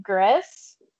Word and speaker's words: Gris: Yeah, so it's actually from the game Gris. I think that's how you say it, Gris, G-R-I-S.